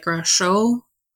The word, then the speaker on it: Gratiot: There's a lot of French names here in Michigan, and we don't always pronounce them the way they should be. Gratiot actually came from Captain Charles Gratiot, who was an army engineer who built the actual military fort just south of the flight station. It was built in 1814. Yeah, from your high school French, it probably should be something more like Gratiot. – Right, that's better than what I said, yeah.